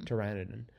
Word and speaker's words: Pteranodon: I think the P is just silent. – Mm-hmm. – Pteranodon.